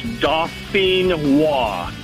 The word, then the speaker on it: Dauphinois: It's, like I said, it's French. It's Dauphinois. – Dauphinois.